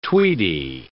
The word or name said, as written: tweedy